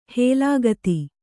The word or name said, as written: ಹೇಲಾಗತಿ